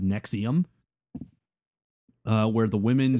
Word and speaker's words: NXIVM: This is spelled differently. It's NXIVM, but it's Nexium.